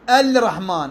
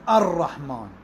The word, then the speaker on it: الرحمن: أقول، ما أقول الرحمن، أقول الرحمن، ما أقول الرحيم، أقول الرحيم، ما أقول الصراط، أقول الصراط، هذا واجب الإدغام، يعني اللام بعد تدغم بالصاد، يعني لا تلفظ وتشد، يشدد الحرف الذي بعده.